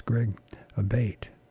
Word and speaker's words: Abate: But I mispronounced his name. I'm apologizing for that. And I've been told, at least, it's Greg Abate.